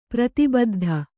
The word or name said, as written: ಪ್ರತಿಬುದ್ಧ